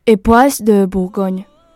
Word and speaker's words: Époisses de Bourgogne: Époisses de Bourgogne.